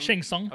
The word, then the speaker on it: Shang Tsung: She's taking Shang Tsung. Shang Tsung. Oh my god. – Shang Tsung. Shang Tsung. – Hold on. I can't fucking. Is it Shang Tsung? – Shang Tsung.